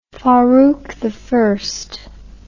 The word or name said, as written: farouki